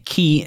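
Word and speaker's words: ki: Yeah, it's key, and again, that's spelled K-I, is life energy, I would say.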